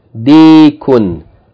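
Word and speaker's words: Dikun (ديك): Del with kasra elongated by ya with sukun. D, and then kaf with tanween. Kun. Dikun. Dikun. A dik is a rooster. So what is this? Ma hadha? Hadha dikun.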